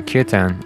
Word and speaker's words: kirtan: The term that I'm going to be using a lot on this show is kirtan, which is spelled K-I-R-T-A-N.